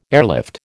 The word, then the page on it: airlift: American English